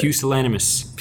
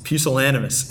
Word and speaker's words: pusillanimous: Pusillanimous. – Pusillanimous.